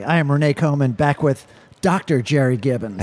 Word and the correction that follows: Giddens: Absolutely. – Giddens, sorry. I always yep. mispronounce everybody's last name.